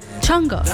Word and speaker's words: Jungkook: O si no, ya me dijeron cómo se pronunciaba. Jungkook. Chongo. Como decir chongo. Chongo.